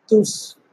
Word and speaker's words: Tsukuba: TSUKUBA, Tsukuba, the city known from its role in scientific development since the 1960s.